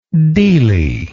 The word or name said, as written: deelie